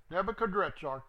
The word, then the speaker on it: Nebuchadrezzar: The word which came to Yirmiyahu from Yahweh when Nebuchadrezzar, they spell it and pronounce it several ways. Nebuchadnezzar or Nebuchadrezzar, it's the same guy. Nebuchadrezzar,